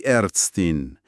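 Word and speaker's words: Ärztin: برای مؤنث میشه دی ارتستین. ببینید، آ تبدیل شد به ا، یعنی ا املات گرفت و یه این هم به آخرش اضافه شد، دی ارتستین.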